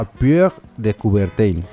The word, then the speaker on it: Pierre de Coubertin: Es esto, que el primer paso de la llama, el primer corredor, se hace frente al monumento en homenaje a Pierre de Coubertin.